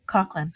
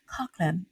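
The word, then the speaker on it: Coughlan: Coughlin. – There it is. Coughlin.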